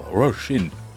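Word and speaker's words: Róisín: Euh, Roshin, prononce-le à l'irlandaise, Murphy.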